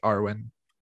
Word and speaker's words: Arwen: I really liked Arwen.